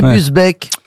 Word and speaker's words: Busbecq: Ogier Gilin de Busbecq, pardon, j'ai écorché son nom. – Il me semble bien aussi. – Est-ce que vous pouvez appeler Busbecq? – B-U-S, B-E-C-Q. Olivier, pardon, Augier Ghislain de Busbecq.